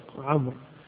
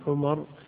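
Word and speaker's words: عمرو: جاء في بعض الناس قال نعم في بعض روايات الحديث، وألف يعني يختصر فنكمل، في بعض الروايات قلت لعمر، الواو تكتب ولا تنطق عمر، لكن للتفرقة بين عمر وعمر، لأنه ما في تفرقة إلا بالتشكيل، والتشكيل غالبا ما يوضع، فتلتبس عمر مع عمر، ففرق بينهما بالواو اصطلاح، إنه عمر يجعل معها واو فهي تنطق بدون واو،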